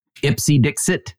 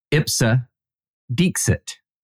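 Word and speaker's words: ipse dixit: Ipsy Dixit, which is how I pronounce it. That is not how it's pronounced. I know that comes as a surprise to you, Sarah, but it is Ipsa Dixit.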